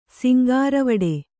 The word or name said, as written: ಸಿಂಗಾರವಡೆ